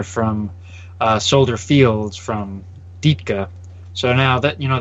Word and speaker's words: Ditka: Uh, I, I, I wanted to pronounce it Ditka, or is it Ditka? – Yeah, well, cop, uh, for copyright purposes, it's Ditka.